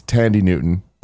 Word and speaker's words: Thandie: And then, yeah, like you said, it's Tandy Newton. – Yep. – Not Thandie Newton.